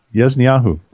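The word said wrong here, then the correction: Yazaniyahu (יאזניהו): Each man It's Yazaniyahu. It's a kind of a difficult name, Yazaniyahu.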